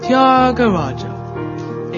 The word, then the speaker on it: Tyagaraja: It's pronounced Tyagaraja.